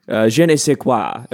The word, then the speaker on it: je ne sais quoi: But they have a certain je ne sais quoi. Am I saying that right? – Yes, I believe you're trying to say too fucking long is how we would describe them.